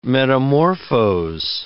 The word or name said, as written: metamorphose